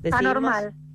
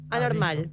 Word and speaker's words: ANRML: Discutimos acá antes de la entrevista cómo pronunciarlo. Anormal. – Anormal. – Ah, ¿Preferís? Bien. Con todas sus vocales.